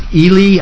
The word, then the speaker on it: Ealy: For Delbert Ely. – Ely. Yep. How do you spell that last name so people know? – E-A-L-Y. – E-A-L-Y. Okay. – E-A-L-Y. It's Ely.